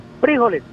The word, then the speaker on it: frijoles: No, no, pero a lo que se refiere el comandante es que es como si tuviera acento en la I. – Correcto. – Frijoles, exacto. Frijoles. Y frijoles. Nosotros es frijoles en la O, ¿no? – Exacto.